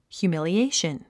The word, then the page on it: humiliation: hjuːmìliéiʃən; ヒューミリエイション